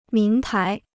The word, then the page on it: 名牌: míng pái